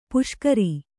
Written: ಪುಷ್ಕರಿ

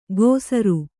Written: ಕೊಸರು

